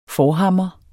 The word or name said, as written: forhammer